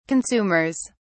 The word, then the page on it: consumers: British English